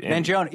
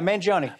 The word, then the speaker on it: Mangione: Mangione. Yeah, Mangione. – Mangione. – Yeah, yeah. I always say it's like Joni Mitchell. Mangione.